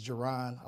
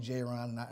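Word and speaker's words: Ja'Ron: You know, I always tell people, they say my name, of course it's Jaron. A lot of people call me J-Ron and I go by it.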